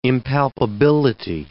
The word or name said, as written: impalpability